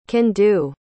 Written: can-do

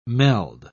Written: meld

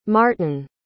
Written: martan

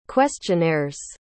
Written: questionnaire's

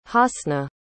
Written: hasna

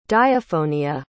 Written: diaphonia

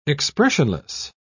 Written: expressionless

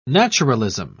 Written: naturalism

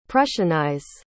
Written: prussianize

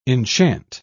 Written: enchant

